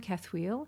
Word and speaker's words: Cathoel: C-A-T-H-O-E-L is her first name, Cathoel. And Jorss is spelt J-O-R-S-S. And, yeah, you have a writer page, don't you? – I do. I've got a Cathoel and the New Government page,